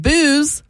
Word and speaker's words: Booze: Like spelled like booze. – B-O-O-Z-E. And just like booze, you spend the night with her and you wake up feeling both regret and nausea.